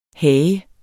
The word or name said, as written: HAGERNES